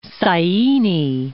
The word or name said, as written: Syene